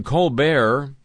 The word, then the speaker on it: Colbert: Stephen Colbert,